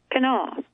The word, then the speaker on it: Kanawha: But we, like the natives, insist the river, county, and state forest are all pronounced Kanawha.